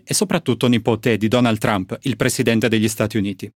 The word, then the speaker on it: Trump: Ovviamente la pronuncia corretta del cognome del presidente e di sua nipote è Trump, ma in italiano ormai ci siamo molto abituati a usare Trump ed è quello che faremo in questa versione doppiata.